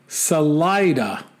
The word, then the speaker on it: Salida: And then he says, Salida is actually pronounced Salida, not to be confused with saliva.